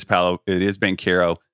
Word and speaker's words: Banchero: Apparently it is Banchero, 6'10".